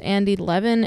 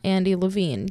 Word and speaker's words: Levin: I'm pretty sure Andy Levin is pronounced Andy Levin and not Andy Levine.